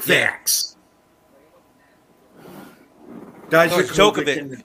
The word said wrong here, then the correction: Djokovic: It's, it's Djokovic. It's Djokovic.